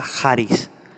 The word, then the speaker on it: charis: La palabrita de gracia en la concordancia de Strong en el idioma griego es el número 5485, que se pronuncia charis, que significa gracia y también significa bondad inmerecida.